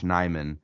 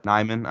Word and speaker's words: Nyman: But, man, my next one here is Myles Garrett versus Josh Nyman. Am I saying that right? – You are. Yep. – Nyman. I want to make sure I'm not putting that J in there on accident.